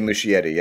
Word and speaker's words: Muschietti: andy muschietti yeah uh-huh.